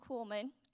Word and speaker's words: Kuhlman: Catherine Kuhlman, let me say it right, Catherine Kuhlman. I used to say Catherine Kuhlman, and my sister would say, she's not a spice in your spice jar.